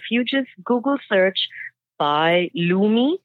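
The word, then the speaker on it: loomi: It's a lumi.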